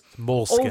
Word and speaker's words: Måneskin: I fucking hate these bands like Greta Van Fleet, Måneskin, whoever the hell they're, however you pronounce that. These bands that just Måneskin.